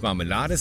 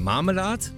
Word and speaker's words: Marmelade: Wir haben einmal die Betonung auf der ersten Silbe, anders wie im Hochdeutsch auf der vorletzten Silbe. Man sagen nicht Marmelade, sondern Marmelade.